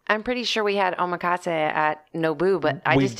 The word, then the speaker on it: omakase: They were talking with us about whether or not they wanted to do and here is the unanswered question answer, omakase. – That's right. They said that, omakase. Is that how you pronounce it? Omakase.